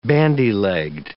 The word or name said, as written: bandy-legged